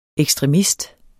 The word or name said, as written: ekstremist